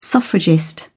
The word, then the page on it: suffragist: British English